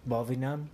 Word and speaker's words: Võ Việt Nam: Võ Việt Nam? – Yeah.